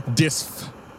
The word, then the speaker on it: Dysph: Disf for assistance. – Disf is the name of the orc, yeah? – Disf. – Yeah, Disf. I heard an F at the end. – Disf, how do you spell that? – Obviously, just like it sounds, D-Y-S-P-H.